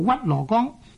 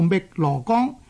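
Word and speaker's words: 汨罗江: 所以呢就有人呢就读做屈罗岗。读呢个屈罗岗呢其实呢就係、是、不大正確嘅。正確嘅读音呢应该呢读做觅罗岗。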